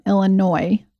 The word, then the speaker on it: Illinois: My friend Liz and probably all other people from Illinois could not stand that I said Illinois with the S involved. – Didn't catch that. Thanks, Liz. – So she said it was like cringy and she could hardly listen, but she is from Chicago. So I can imagine everybody from like up there is like, that's not how you say it.